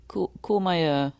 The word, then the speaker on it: Courmayeur: Is it Courmayeur? – Courmayeur. – Okay, yeah.